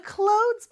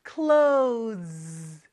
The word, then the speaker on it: clothes: 자, clothes 발음이 조금 어려울 수도 있는데요. 잘 따라해 볼까요? Clothes, clothes. 네, 그렇게 끝에 z 발음 나는 거 많이 연습해 주세요.